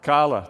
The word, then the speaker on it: challah: I'm probably not going to pronounce this correctly, but it's chahuah, maybe? Bread? What? Challah. Thank you. I knew I messed that up.